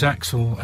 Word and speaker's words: Aczel: That's Aczel. – I'm glad you said that because I couldn't pronounce it until today. – Yeah, it's Axel as in Axel Foley or. Axel Rose.